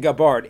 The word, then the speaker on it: Gabbard: How is her last name pronounced? – Well, I'm saying Gabbard. It's an English name.